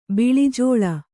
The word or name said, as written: ಬಿಳಿಜೋಳ